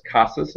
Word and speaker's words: Casas: Again, my name is Jimmy, and my Twitter handle is Casas. It's spelled C-A-S-A-S, like me casa, su casa, but it has an S on the end.